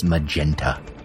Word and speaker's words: magenta: And I'm a light purplish red that is one of the primary subtractive colors complementary to green, better known as Magneta. – Wait a minute. No, I think that's pronounced magenta.